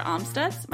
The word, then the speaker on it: Amstutz: And you can find me on all social platforms at Sandra Amstutz. My last name is spelled A-M-S-T-U-T-Z.